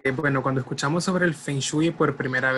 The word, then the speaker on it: Feng Shui: Eh, no solamente el Feng Shui o Feng Shua, como realmente se pronuncia,